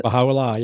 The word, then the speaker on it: Baha'u'llah: Is that how you pronounce it? – Baha'u'llah, yes.